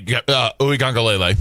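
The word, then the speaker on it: Uiagalelei: Hey, I need your help because I know you know how to say it. Ugalalali. – Okay, Uigangalele.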